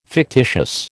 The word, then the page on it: fictitious: American English